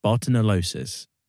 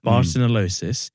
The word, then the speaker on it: Bartonellosis: And it is, I can't pronounce it properly, it's Bartonellosis. That's how it's pronounced. – Bartonellosis.